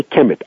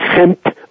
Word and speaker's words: Kemet: They didn't write vowels, so all we get down is the signs as K-M-T, Kempt. We say Kemet. I've spelled it. It's been spelled many, many different ways. As with this ancient language, spelling is not important. Pronunciation and sound is the most important. So people can spell it K-E-M-E-T, K-E-M-I-T. I spell it K-H-E-M-I-T. It's Kempt or Kemet.